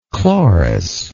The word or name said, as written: Cloris